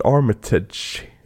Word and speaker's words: Armitage: För just nu kollar vi på scenen när han precis har blivit Captain America och springer då efter Richard Armitage, uttalas det väl?